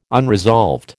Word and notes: unresolved: American English

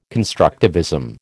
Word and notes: constructivism: American English

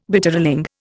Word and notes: Bitterling: Indian

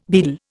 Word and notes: beadle: Indian

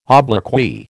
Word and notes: obloquy: American English

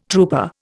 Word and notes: trooper: Indian

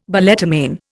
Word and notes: balletomane: Indian

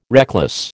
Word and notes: reckless: American English

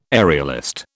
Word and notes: aerialist: British English

